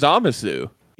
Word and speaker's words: Zamasu: I was about to say, I think one of the best like Goku rage moments is in fucking Super against Zamasu.